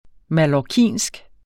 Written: mallorcinsk

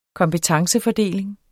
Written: kompetencefordeling

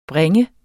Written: bringe

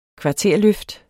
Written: kvarterløft

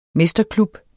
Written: mesterklub

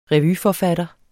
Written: revyforfatter

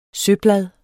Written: søblad

